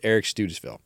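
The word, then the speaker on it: Eric Studesville: So the associate head coach and also the running back coach is Eric Studisville. And I went for pronunciation to Travis. Thanks, Travis. Appreciate that. I was like, I knew I blew this pronunciation last year. How do you pronounce it?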